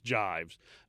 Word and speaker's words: gyves: and I'm told that it's pronounced Jives, G-Y-V-E-S, but I'm told it's pronounced Jives.